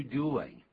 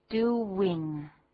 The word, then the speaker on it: doing: 一开头 what are you doing? 你在干什么 ?What are you doing? 这个 doing 啊不要念成 doing 啊不是 doing, 那怎么念的 ?doing. Doing. 因为 do 后面啊就是一个 u 的音 ,u 跟 w 有关系所以因为有连音啊下个音节就是一个 w 的音开始 ,doing.